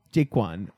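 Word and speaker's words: Jaykwon: After her boyfriend, Jake, fuck, I can't say his name. It's J-A-Y-K-W-O-N. – Jaquan? – Jaquan, okay.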